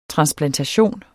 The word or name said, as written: transplantation